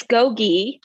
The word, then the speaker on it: Gogi: I don't know if I'm saying this correctly, but is it called Gogi Yoga or is it just G-O-G-I? – So it's Gogi. – Oh, Gogi. – Like Gogi, okay.